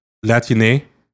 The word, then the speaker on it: Latine: I think in Spanish it's pronounced Latine. – Okay. – Yeah, I just want to make it clear. So like, Latin sounds like latrine.